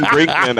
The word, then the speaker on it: Brinkman: There's two N's in Brinkman, Alex.